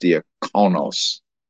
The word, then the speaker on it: diakonos: and it's pronounced diakonos. So it's just a transliteration.